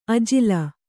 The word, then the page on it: ಅಜಿಲ: ajila